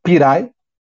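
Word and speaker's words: Pirae: É o Pirai SA, é assim mesmo que pronuncia, Pirai.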